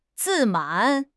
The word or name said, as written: 自满